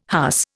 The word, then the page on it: huss: Indian